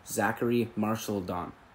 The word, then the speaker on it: Zachary Marchildon: Please welcome to the podcast former University of Waterloo basketball player, now nutritional and strength coach, also wellness coach, Zachary Marchildon.